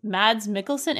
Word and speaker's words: Mads Mikkelsen: Also getting massive props from me in this movie is Mads Mikkelsen. Am I saying that incorrectly? – Yeah, you got it.